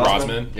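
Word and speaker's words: Rozman: Because he is that you pronounce Rosman? – Rosman. Yeah, it's pronounced Rosman.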